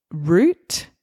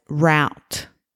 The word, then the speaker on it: route: So I would say root, R-O-U-T-E, and SA pronounced it as route. Now, sometimes I have pronounced this word as route. I switch between the two, but you'll hear that. Some people might say root and some people might say route.